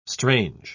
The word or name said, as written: strange